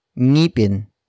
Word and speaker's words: ngipin: And that is ngipin, which is spelled as ng, I, P, I, N.